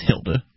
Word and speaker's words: Hilde: H-I-L-D-E, that's pronounced Hilda, right? It's yeah. Like an old lady's name.